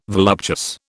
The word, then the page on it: voluptuous: British English